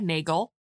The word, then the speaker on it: Nagel: Beautiful and high-spirited Lady Isabella, played by Anne Harding, is marrying ambitious landowner Robert Carlyle, played by a silent idol Conrad Nagel, as in bagel. I had been pronouncing it with a soft G in previous episodes, but how many things have you seen him in so far?